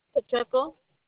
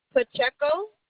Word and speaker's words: Pacheco: A-N-D-R-E-A. – Yes. – And what's your last name? – Pacheco. – What was that? – Pacheco. – Spell it for me. – P-A-C-H-E-C-O. – P-A-E-C-H-E-O. – No. P-A-C-H-E-C-O. – P-A-C-H-E-C-O.